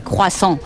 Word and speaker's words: croissant: A ver, pues un francés dice croissant. Lo que pasa para un español es un poco complicado de decir, y nosotros decimos siempre croissant.